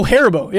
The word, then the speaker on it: Haribo: Like, you said Karibo? – Haribo? – Like the Yu-Gi-Oh monster? – Haribo? I don't know how to say it. – Oh, Haribo. Yeah. Haribo. Okay, yeah.